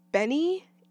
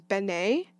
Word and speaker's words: BENEE: The other project I've been excited about is Lychee by Benny, in all caps again. It's funny because in my head I've been pronouncing it as Bene, but I think it's actually just Benny. B-E-N-E-E. Singer of the song Super Lonely.